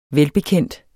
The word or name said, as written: VELBEKENDT